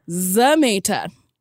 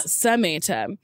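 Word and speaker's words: Zamata: I said her name wrong, too. – I was like, that's a different pronunciation it than is. I've heard. – Sashir Zameta. – Perfect. – Not Zameta.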